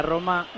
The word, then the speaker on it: Roma: y mismamente en la pronunciación de la Roma, todo el mundo la pronuncia mal, porque esa R no es fuerte.